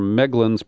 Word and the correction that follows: Melgen: problems. I'm sorry, it is Melgen. I mispronounced his name.